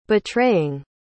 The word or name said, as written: betraying